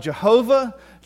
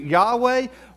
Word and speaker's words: Yahweh: The old German scholars used to call it Jehovah. Today we tend to pronounce it Yahweh. We're not really sure exactly, because the old Hebrew Testaments, they didn't have vowels.